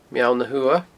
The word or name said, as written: Meall na h-Uamha